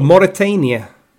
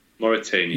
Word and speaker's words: Mauritania: Mauritania. – Mauritania.